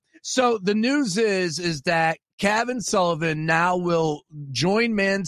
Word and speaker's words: Cavan: Kayvon, how do you say his first name? – Cavan. – There Cavan. You go. It'd be cooler if it was Kayvon.